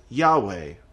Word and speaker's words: Yahweh: We pronounce it Yahweh, although we don't exactly know how to pronounce it because there weren't any vowels in the Hebrew language, and the Hebrew people did not pronounce it.